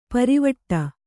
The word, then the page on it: ಪರಿವಟ್ಟ: pari vaṭṭa